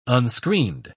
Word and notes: unscreened: British English

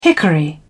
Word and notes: hickory: British English

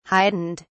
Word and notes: heightened: British English

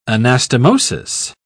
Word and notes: anastomosis: British English

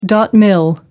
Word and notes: mil: American English

